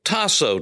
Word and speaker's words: tasso: Hupo, H-U-P-O, which is usually translated or transliterated hypo with a Y instead of U. Hupo, Tasso, T-A-S-S-O, long O at the end. Tasso, T-A-S-S-O.